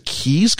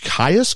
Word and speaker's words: Caius: Is it Keys College? Caius College? I think this is Welcome to Keys later on. – So anyway, I think it's Keys.